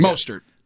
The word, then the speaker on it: Mostert: No, Coleman, man, and Mustafi, how you say his name? – They Mostert.